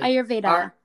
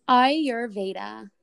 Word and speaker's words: Ayurveda: Ayurveda. – Say it again, please. – Ayurveda.